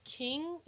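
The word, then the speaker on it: Qing: And I think it's King is how you pronounce it. It's Q-I-N-G.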